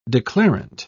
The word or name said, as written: declarant